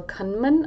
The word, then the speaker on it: Kahneman: I've been reading this book recently called Thinking Fast and Slow by Daniel Kahneman.